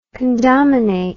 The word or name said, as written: condominate